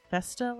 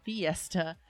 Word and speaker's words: FESTA: But let me start off, first things first, I want to apologize because I mispronounced FESTA last week. I called it Fiesta because apparently I have party on the brain. Just wanted to put that and correct it.